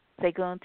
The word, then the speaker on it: Seguin: And Segun, S-E-G-U-I-N, how do you pronounce that? – Segun, Texas.